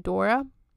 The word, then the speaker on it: Dore: This would be changed by a couple of Germans named Friedrich Ritter and Dora Strauch Corwin. And it's funny because her name is spelled D-O-R-E, but you're supposed to pronounce her name Dora, like Dora the Explorer.